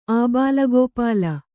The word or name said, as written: ಆಬಾಲಗೋಪಾಲ